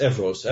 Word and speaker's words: Evros: There was another, is it Evros that it's pronounced, another area? – Yes, Evros.